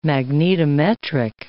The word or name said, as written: magnetometric